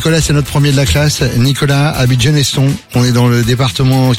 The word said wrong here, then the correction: Geneston: Nicolas, j'ai dit Geneston, c'est Geneton. On prononce pas le S.